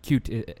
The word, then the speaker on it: Qt: So the idea is that you would use this, that you would use Qt, or Qt as it's actually pronounced by the folks that made it in the first place.